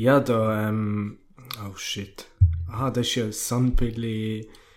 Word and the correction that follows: San Pellegrino: Alter, ich kann das nicht aussprechen. San Pellegrino.